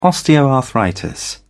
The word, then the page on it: osteoarthritis: British English